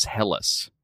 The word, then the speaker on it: Helles: Which I just learned how to pronounce. I was not sure what I was supposed to say for that. Helles. But it is Helles. – According to the Washington Post, that is correct.